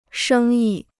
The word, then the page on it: 生意: shēng yi